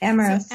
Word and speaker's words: Amyris: The other case um, takes us into Amyris. Is that am I pronouncing mm-hmm. that sort of yep, right? Amyris.